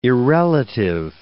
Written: irrelative

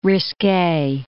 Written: risque

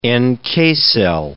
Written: NK cell

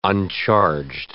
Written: uncharged

